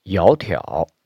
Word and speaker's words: 窈窕: 这两个字呢，都是三声，它们组词以后，第一个三声就读如阳平。也就是读如二声，就读作窈窕。